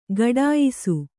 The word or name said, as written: ಗಾಠಾಯಿಸು